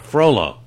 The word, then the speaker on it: Frollo: William Lucas Franklin, who is Pierre, Kiara Elder, who is Esmeralda, and Frullo, am I pronouncing that correctly? – Frollo.